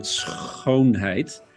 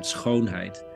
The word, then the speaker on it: schoonheid: Well, there's this famous Dutch word, schoonheid. If you pronounce it and it hurts a little bit, you're saying it right, which has two meanings.